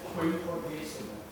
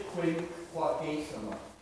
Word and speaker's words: Quinquagesima: Quinquagesima. – Say it again? – Quinquagesima.